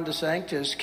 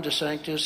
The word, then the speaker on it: DeSantis: And they said, and ladies and gentlemen, we have Ron DeSantis here. And they scream, no, it's DeSantis, you know.